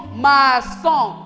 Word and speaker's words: maçon: Maçon, ça se prononce comme la lettre S. Mais dans le mot case, le C là-bas se prononce comme la lettre K.